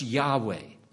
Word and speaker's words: Yahweh: Not the lowercase, which is a different title entirely, but the uppercase, the capital letters, O-R-D. It is the Hebrew Yahweh. Some have used Jehovah for it, but the title should be properly pronounced Yahweh.